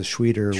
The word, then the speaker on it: Schwieter: S-C-H-W-I-E-T-E-R. – Schweitzer. Schweiter. – Schweiter. – Schweiter. – So the German pronunciation of I-E goes to E, E-I goes to I. So if it was E-I-T-E-R, it would be Schweiter, but it's Schweiter. – Because it's I-E.